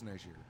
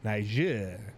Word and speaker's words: Niger: I like how you say it all French-like. – That's what it's called, is Niger. – You're just like, Niger.